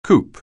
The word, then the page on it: coop: anglais